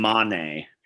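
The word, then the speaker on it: Mané: But and just to help a neophyte, it's Jota and Mane. So just helping with the pronunciation there.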